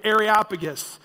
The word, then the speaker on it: Areopagus: Everybody said Areopagus. Yeah, big, funny, goofy word, but it's fun if you say it right. Areopagus.